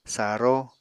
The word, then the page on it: saró: apatani